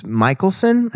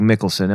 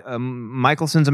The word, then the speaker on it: Michelsen: Alex Michelson. I think it's Michelson. It looks like Mickelson. M-I-C-H-E-L-S-E-N.